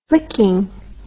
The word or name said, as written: flicking